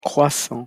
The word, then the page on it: croissants: French